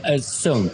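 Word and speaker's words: Xuan: And we're sitting in your restaurant, Zuan. Have I pronounced that correctly? – Soon. – I wasn't even close. – It's basically like summer, like soon.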